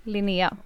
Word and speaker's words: Linnea: Linnea.